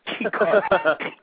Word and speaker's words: Chikara: Is that how you say it? Chikara? – Chikara.